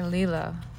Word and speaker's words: Leila: Okay, Leila. I say it like that because they um, they write how to pronounce it in the book, and I, I love that when when people write that way.